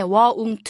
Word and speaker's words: won't: 여기서 won't to의 여러분 발음 좀 어려운데요. 한국 사람들 굉장히 won't to 발음하기 어렵고요. 저도 이거 발음 처음 연습할 때 won't to 이렇게 써놓고 연습했거든요. 그걸 빨리 하셔서 won't 이렇게 입 모양을 우로 만드신 다음에 won't라고 발음하시는 거거든요.